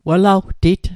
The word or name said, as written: welawtit